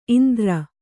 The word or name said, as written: ಇಂದರ